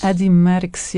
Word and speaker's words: Merckx: Eddie Merckx, yes.